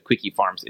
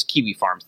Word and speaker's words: Kiwi Farms: Maybe I said Quickie Farms. It's Kiwi Farms, like the fruit.